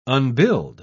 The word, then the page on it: unbuild: British English